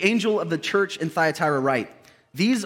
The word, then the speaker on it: Thyatira: There is some debate about the pronunciation. You could say it how you'd like to. I'm going to say Thyatira.